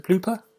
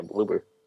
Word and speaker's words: Bloober: Is it Blooper? how do you say it? Yep. Yeah, Bloober. Bloober.